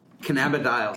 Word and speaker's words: cannabidiol: It's, first of all, CBD is cannabidinol. Is that correct? – I guess. – How do you say it? – This is my pronunciation is, now I'm going to say it wrong. cannabidiol. – Cannabidiol. Okay. – Yes. This is the Canadian pronunciation that you hear at the medical conferences.